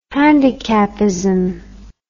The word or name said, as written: handicapism